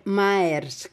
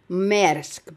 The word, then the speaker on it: Maersk: Μέρσκ, γράφεται Μαέρσκ, αλλά Μέρκ το λέω εγώ γιατί είναι η εταιρεία των φαρμάκων, των κτηνιατρικών και του μεγάλου βιβλίου των κτηνιατρικών και μου έχει μείνει από εκεί, κατάλαβες. Ναι, Μέρσκ προφέρεται. M-A-E-R-S-K, άμα θες να το ψάξεις.